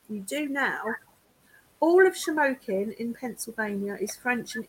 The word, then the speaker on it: Shamokin: And you pronounce that with no problem. – What? – Shamokin. – Shamokin. – Right. Bravo. Spoken like you lived in PA your whole life.